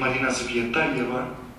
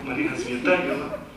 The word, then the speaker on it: Marina Tsvetaeva: yo hice un curso para pronunciarla y parecer que me lo sé, que es Marina Tsvetaeva. ¿Qué tal? Parece, ¿no? Marina Tsvetaeva.